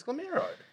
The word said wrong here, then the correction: Guillermo: Guillermo. – You. – Guillermo. – I'm sorry. – It's like Guillermo del Toro. That's how you pronounce it.